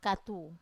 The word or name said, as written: katu